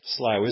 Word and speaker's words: Slough: Now, who knows whether Slough was named after the Slough of Despond. Slough isn't such a bad place. The Americans get round it by pronouncing it the Slough of Despond.